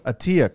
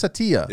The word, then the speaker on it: Attia: Attia. Chris Attia.